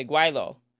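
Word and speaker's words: gweilo: They say Guaylo. I love that I can pronounce it correctly.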